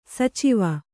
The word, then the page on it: ಸಚಿವೆ: sacive